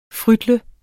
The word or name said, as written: frytle